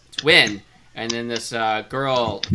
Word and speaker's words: Nguyen: No. Nguyen. It's almost two syllables.